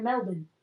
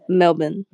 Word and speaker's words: Melbourne: Oh, I can say it right now, Melbourne. – Your L is still a little bit too L-y. – How should I do it instead? – Melbourne. There we go.